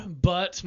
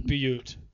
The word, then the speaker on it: Butte: Okay, Square Butt, Montana. Okay, it's actually pronounced Butte.